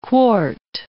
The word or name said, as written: quart